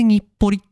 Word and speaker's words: 日暮里: 今日のスタートは、日暮里。名前がすごいですよ。日本人の私からしてもですね、日暮里って、これ読めないですし、まあ書けますけど、これで日暮里って読むんだっていう、びっくりですよね。